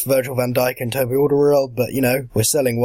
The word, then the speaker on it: Alderweireld: I think we can never pronounce. – Alderweireld. – Yeah. – Yeah, we had him at Saints for a year. We learned how to pronounce his name.